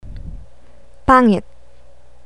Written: pangit

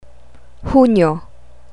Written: hunyo

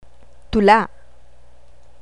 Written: tula

